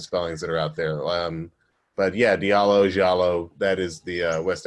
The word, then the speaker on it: Diallo: Diallo is how I pronounce it, and it's sort of the anglicized version of the name. It could be pronounced Jallo, because over there they have a place that looks very much like my name called the Futa Jalon Mountains.